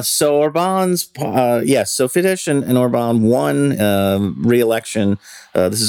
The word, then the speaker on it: Fidesz: It was not terribly surprising that Viktor Orban's party, his Fidesz party, I'm not sure if I'm pronouncing it, I'm probably butchering that, I'm sorry. – I pronounce it Fidesz.